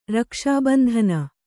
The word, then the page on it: ರಕ್ಷಾಬಂಧನ: rakṣābandhana